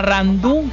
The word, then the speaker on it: Randú: Sí, yo tengo un tío que se llama Randú, con acento en la U, y el significado de él, sí, como bien dices tú, es un nombre gitano, significa hombre valiente.